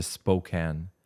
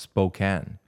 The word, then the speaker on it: Spokane: Je veux juste prendre un moment pour féliciter Jennifer dans la prononciation de Spokane. Même les Américains, la plupart des Américains, mal prononcent Spokane. – Spokane? – ils vont dire Spokane.